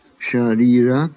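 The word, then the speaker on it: śarīra: Place the tongue against the teeth as though to pronounce the English word chair. Now move the tongue away from the teeth and pronounce the word share, and you will pronounce sharira correctly. The sha sound is pronounced as in she or ship.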